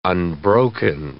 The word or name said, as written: unbroken